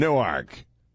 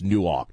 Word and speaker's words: Newark: Nowhere in that book is there a poem about the blonde chick I had in the apartment in Newark. Oh, and it is pronounced Newark, Delaware. It's not Newark. Newark is New Jersey. Newark is Delaware.